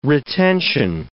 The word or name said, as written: retention